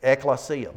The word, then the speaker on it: ekklesia: That ek is actually more like, if we were, well, the way it's spelled, if you will, dropping it into English letters, it's E-K-K-L-E-S-I-A. But it's more similar to that red sign there. Exit. Out of.